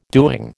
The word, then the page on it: doing: American English